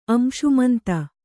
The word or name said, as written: ಅಂಶುಮಂತ